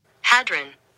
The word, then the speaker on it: hadron: Did you say hadron? – No, I said hedron. So hadron. It's hadron, the hadron collider. – Yeah, hold on. Let me hold on. Give me one second. – Hadron.